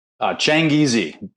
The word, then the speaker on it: Changizi: Changizi. It's actually Genghis Khan. Changizi. It's the word for Genghis Khan.